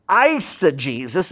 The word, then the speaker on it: eisegesis: Eisegesis, and the way you remember that is the first syllable there in that word, I.